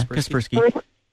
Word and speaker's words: Kaspersky: We like Kaspersky, right? Kaspersky right now. I think that's how you pronounce it, right? – Yeah, Kaspersky.